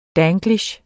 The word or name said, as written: danglish